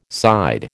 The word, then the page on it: side: American English